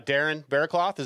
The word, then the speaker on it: Berrecloth: Uh, Darren Berrecloth, is that how you say it? – Yeah.